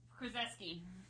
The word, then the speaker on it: Krzyzewski: Mike Krzyzewski. – Krzyzewski is his name. – Get fucked. You can't just make up a pronunciation. Yeah. Spell it. Will you spell the last name for me, T? – K-R-Z-Y-Z-E-W-S-K-I.